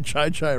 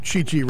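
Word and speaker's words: Chi Chi: He pronounced it Chai Chai Rodriguez. – Rodriguez. Okay. – Instead of Chichi Rodriguez.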